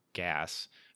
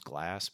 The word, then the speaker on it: Gass: Now, this is a movie based off the band Tenacious D, which is Jack Black and Kyle Gass. I always want to say his name's Glass, but it's not.